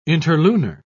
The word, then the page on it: interlunar: British English